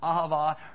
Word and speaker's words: ahava: It actually comes out of the word ahav, but it has a little tag on the end, so it's pronounced ahava.